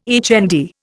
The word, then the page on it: HND: Indian